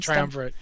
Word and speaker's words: Triumvirate: And back on Argus, I think out of the Triumvirate, is that how you pronounce that? – Yeah, the Triumvirate, yeah.